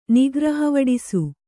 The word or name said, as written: ನಿಗ್ರಹವಡಿಸು